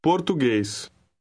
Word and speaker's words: português: The G is pronounced as in the English word go. Try 4. – Português.